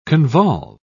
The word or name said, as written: convolve